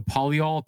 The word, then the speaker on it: polyol: Is it called the polyol pathway? – Yeah.